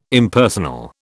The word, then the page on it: Impersonal: British English